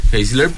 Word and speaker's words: Geisler: Bueno, ya leí de Edwin, que era justamente de Frank Turek y Norma eh, Geisler, no sé si está bien pronunciado Heisler. – Heisler.